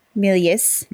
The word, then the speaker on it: Méliès: Yeah, you pronounce the S. – Méliès.